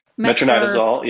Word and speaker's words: Metronidazole: And then there's one more that I don't know if I'm saying it right. Metronidazole, is that right? – Metronidazole. Yeah.